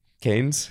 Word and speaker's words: Cannes: Then Siebel's having another conference in Cannes. Is that how you pronounce it? – Cannes.